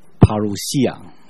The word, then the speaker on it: Parousia: 那个字其实希腊文应该读作 Parousia，